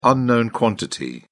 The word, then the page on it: unknown quantity: British English